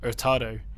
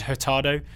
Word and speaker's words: Hurtado: It was more that basically when I pronounced the name of the Spanish player, Moises Hurtado, I should have pronounced it like that and not pronounced the letter H at the front of his name. So I said Hurtado, which threw Taylor off, and as a result, he got that name wrong in the spelling bee.